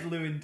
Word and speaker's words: Llewyn: The film is Inside Llewyn Davis, not Llewellyn Davis.